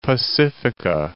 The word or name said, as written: Pacifica